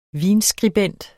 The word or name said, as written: vinskribent